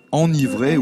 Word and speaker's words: enivrer: Alors communément, on dit enivrer. Mais il y a peut-être un piège et une difficulté. Quelle est l'orthographe du verbe enivrer ou s'enivrer? – C'est E-N, c'est V, R, I, c'est E-N-I, enni.